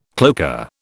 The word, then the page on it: cloaca: British English